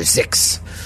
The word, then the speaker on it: Xix: Z-A-Z. Yeah. She is spelled X-I-X. And so I called her Zix.